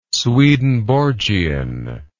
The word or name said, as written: swedenborgian